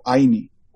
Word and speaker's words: Ayni: So there's a concept in the Inca culture called Aini. And I want to say that Aini is one of the pillars of Mikuna. It's spelled A-Y-N-I.